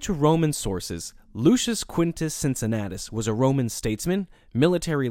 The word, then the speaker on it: Cincinnatus: In Latin, his name would have been pronounced Cincunatus with hard C's, but since the name is more familiar in the English language in its anglicized form with soft C's, I'm going to stick with that pronunciation over the course of this episode.